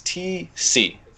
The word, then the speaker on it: Tecce: My last name is T-C. It's spelled T-E-C-C-E, but pronunciation, it's like a letter T and a letter C.